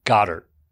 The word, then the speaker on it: Goedert: You called the Eagles player Dallas Goedert? – Yeah. – No. His name is pronounced Goddard. – Yeah, as in God.